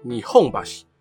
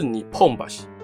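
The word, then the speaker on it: 日本橋: 所以东京的日本桥才会念作你哄吧西，而不是你碰吧西。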